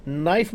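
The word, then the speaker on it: knife: नाइफ में क्या silent होता है? – K.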